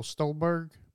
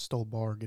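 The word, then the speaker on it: Stuhlbarg: Michael Stolberg. It's Stolberg, isn't it? – Nope, you said it right.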